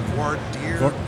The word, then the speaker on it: voir dire: And there was a presentation one time on voir dire, or how is that pronounced? When you're choosing the jury, the voir dire? – Yes, exactly.